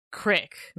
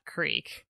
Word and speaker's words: creek: If you live in central Illinois, that is pronounced crick, not creek.